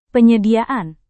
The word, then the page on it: penyediaan: indonesian